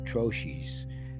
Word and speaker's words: troche: Okay, the technical term for what I was calling lozenges is spelled T-R-O-C-H-E, and I believe that's pronounced troches, but it's spelled T-R-O-C-H-E